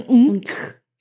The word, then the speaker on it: Untch: Spricht man Unck? Ich glaube so. – Aha, ja. – U-N-T-C-H, was soll ich machen? Unck. So spricht man den Namen.